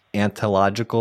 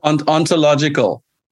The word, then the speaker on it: ontological: It was to do those things, to be curious and to care about these people, and that new word, ontological, or, you know, making sure that whatever is, how do you say it? – Ontological. – Ontological, yeah.